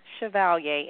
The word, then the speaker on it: Chevalier: Yes, you can find me on Facebook, Katrina Chevalier, and it's C-H-E-V as in Victor, A-L-I-E-R-Cormier, C-O-R-M, C-H-E-V-A-L-I-E-R. Again, that's Katrina Chevalier-Cormier. It is a little hard to pronounce. It's a Creole descent name.